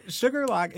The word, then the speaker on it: Shuqualak: So Sugarlock is actually spelled S-H-U-Q-U-A-L-A-K. Bow.